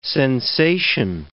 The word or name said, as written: sensation